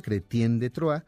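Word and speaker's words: Chrétien de Troyes: Aquí vale la pena decir que su aparición literaria se presenta en una obra escrita por Cretien de Troyes, así se pronuncia Cretien de Troyes, que fue escrita en el año 1176 en el siglo II entonces.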